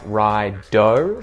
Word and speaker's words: righto: This is short for all right, and we've just taken right and we put an O on the end and we pronounce it like ride-o, so it's a D sound, ride-o.